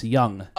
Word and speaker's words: Jung: No, was it Young or Jung? – It was the Young Dragons. – Oh, on Wikipedia it says Jung. – Well, I know, but it's pronounced Young.